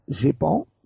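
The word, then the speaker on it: GEIPAN: Más tarde, en 2005, se creará el GEPAN, pero esta vez con una I de más, se pronuncia igual, pero no se escribe igual, sería GEPAN en español, sí.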